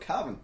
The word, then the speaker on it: Cavan: As a accountant man, I get the, you know, are you from Cavan? – Yeah. – Oh my God. – Actually, I am. – That rhymes gloriously. Yeah. Oh my God. – Well, it rhymes.